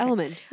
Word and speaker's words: Element: Is that how you say it? – Yes, okay. Element. Yeah.